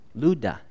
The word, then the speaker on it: Lydda: Lydda. That's how you say it, Lydda. If you're used to saying Lydda, go ahead and say Lydda, just don't say Lydda.